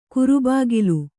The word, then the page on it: ಕುರುಬಾಗಿಲು: kurubāgilu